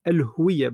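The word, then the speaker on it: الهُوِيَّة: موضوع الهويه بالضمه جايه من كلمه هو راهو كلمه الهويه جايه من الهاويه فمعنى مختلف جدا فاحنا نحاول نركز لما ننطق نقولوا كلمه الهويه بالضمه فاحنا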